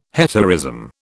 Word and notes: hetaerism: British English

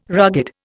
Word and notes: rugged: Indian

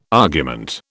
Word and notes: argument: British English

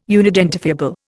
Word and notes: unidentifiable: Indian

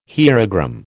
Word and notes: hierogram: American English

